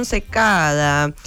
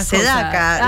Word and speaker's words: Secada: Pero bueno, vamos a seguir con la próxima opción, Just Another Day, de John Secada. Primer sencillo del álbum debut del cantante cubano norteamericano John Secada, que me cuesta muchísimo pronunciar su apellido porque la dislexia me hace decir Sedaka.